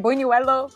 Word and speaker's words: buñuelo: Oh, I gotta go with the buñuelo. The buñuelo is always... Am I saying that right? Buñuelo? Buñuelo? – Yeah, no, you're saying it right.